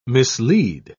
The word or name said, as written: mislead